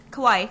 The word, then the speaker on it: kawaii: Kiwan? Kawaii. – Oops. No, no, no. It's different. It's. Spelled like that. Oh, it is kawaii.